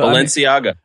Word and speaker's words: Balenciaga: So, Balenciaga.